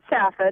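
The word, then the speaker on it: Saffa: I pronounce it Safa. It's really hard to spell over the phone. It's S-A-F-F-A.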